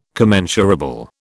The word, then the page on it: commensurable: British English